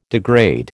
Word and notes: degrade: American English

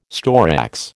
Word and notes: storax: American English

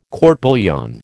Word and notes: court bouillon: American English

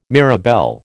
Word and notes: mirabelle: American English

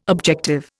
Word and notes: objective: Indian

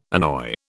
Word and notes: annoy: British English

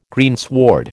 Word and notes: greensward: American English

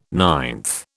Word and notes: ninth: British English

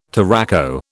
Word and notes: touraco: British English

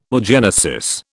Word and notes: oogenesis: British English

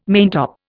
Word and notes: maintop: Indian

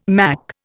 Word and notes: Mach: Indian

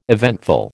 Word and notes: eventful: American English

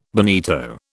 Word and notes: bonito: British English

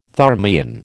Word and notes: thermion: American English

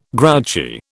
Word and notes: grouchy: British English

